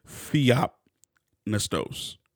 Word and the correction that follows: Theopneustos: Nustos. Theopnustos. That's what it is.